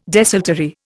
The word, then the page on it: desultory: Indian